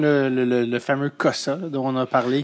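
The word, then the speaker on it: Cossa: Euh, faut être le plus québécois possible quand on prononce son nom de famille. Cosa.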